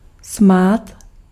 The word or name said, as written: sparkle